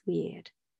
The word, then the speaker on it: wyrd: Now, the word, our English word weird, originally came from the Viking word weird, which is spelled W-Y-R-D.